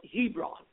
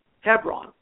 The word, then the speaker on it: Hevron: You know, Christians call it Hebron. But the proper Hebrew pronunciation in Israel is Hebron, H-E-V-R-O-N. Now, Hebron is mentioned in the book of Genesis, where Abraham purchases the Machpelah cave to bury his wife, Sarah.